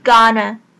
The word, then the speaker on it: gonna: 我们知道 gonna 它其实它的后面那个 a 的音呢被弱化了，不是 gonna 而是 gonna。